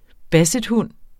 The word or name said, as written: bassethund